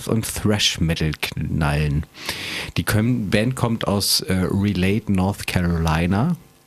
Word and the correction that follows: Raleigh: Du kannst das natürlich viel besser aussprechen als ich, aber. – Das heißt Raleigh. – Raleigh, okay.